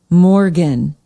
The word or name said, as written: Morgan